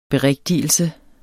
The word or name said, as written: BERIGTIGELSEN